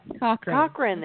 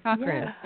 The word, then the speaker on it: Cochrane: I say my name, Teri Cochrane. – Cochrane, there we see. – Cochrane, yeah. Okay. Wanted to make sure.